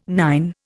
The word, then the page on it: nine: Indian